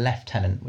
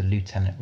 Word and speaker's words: lieutenant: So when did the language change from a lieutenant, which is the UK British way, to lieutenant, which is the American pronunciation?